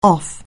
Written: off

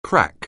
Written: crack

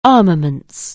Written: armaments

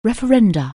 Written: referendum

